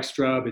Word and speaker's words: Strub: So the name Mark Strub is spelled S-T-R-U-B as in boy. It looks like Strub. It's pronounced Strub.